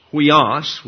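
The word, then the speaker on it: huios (υἱός): Thus, huios is pronounced huios with the accent on the second syllable, and consequently the first Greek letter in the word is the upsilon, or what we would call the U.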